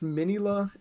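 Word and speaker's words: Minilla: And then like he talks to, I think it's Minila is how they say it. Son of Godzilla's name is Minila. Yeah, like Minizilla, Minila.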